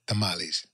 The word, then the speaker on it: tamales: And I got to say, tamales, not tamales. It's tamales.